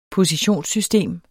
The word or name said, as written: positionssystem